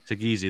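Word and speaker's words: Saggese: Is it Sagesi? – Sagesi, there we go.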